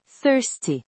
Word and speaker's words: thirsty: Coloquem a língua entre os dentes e soprem levemente. Thirsty. Não é thirsty ou thirsty, é thirsty.